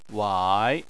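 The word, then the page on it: wai: tone 3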